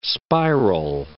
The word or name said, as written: spiral